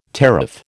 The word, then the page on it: teraph: American English